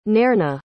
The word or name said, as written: nairna